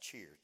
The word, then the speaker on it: cheir: The word is seer, pronounced cheer, C-H-E-I-R.